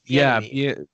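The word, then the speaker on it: Bieniemy: It's Bienemy.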